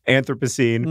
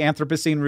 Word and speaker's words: Anthropocene: One, making a good podcast, but more importantly, working very hard to have this word not be pronounced Anthropocene. – I really am. Like, I don't know how it's all going to shake out, how this GIF thing is going to go in the end. – Yeah. – But I'd like to think that if I have one historical legacy, right. – it will be helping to cement the pronunciation Anthropocene, which is obviously better.